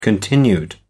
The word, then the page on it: continued: American English